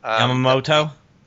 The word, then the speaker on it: Yamamoto: And even in World War II, when we got a chance to see Yamato? How do you pronounce that general's name? – Yamamoto. – Yamamoto, sorry. You know, he almost realized, you know, here he lives by the warrior code.